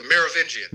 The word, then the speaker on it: Merovingian: The Merovingian.